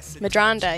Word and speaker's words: Medranda: I think I can say your last name finally. Madronda. – Madronda, yeah. – I got it.